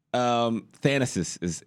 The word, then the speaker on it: Thanasis: Thanasis is how you pronounce it.